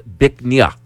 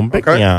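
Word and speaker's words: Bích Ngà: It's spelled B-I-C-H-N-G-A. It's pronounced Bic Nha, which loosely translates to beautiful jade. – Oh, Bic Nha.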